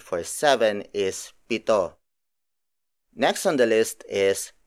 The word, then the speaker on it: pito: Up next is pito.